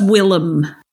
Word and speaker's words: Willem: It's Willem. – Willem, thank you.